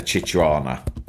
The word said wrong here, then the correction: chihuahua: But not a chichuana, a chihuahua.